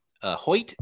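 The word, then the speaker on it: Hjalte: Hoyt is how you pronounce his first name, I believe, right? – Yeah, I can't go there.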